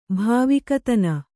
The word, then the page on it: ಭಾವಿಕತನ: kannada